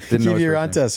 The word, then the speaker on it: Kiviranta: We couldn't get Kitivar. – No, Kiviranta. – Kiviranta, sorry.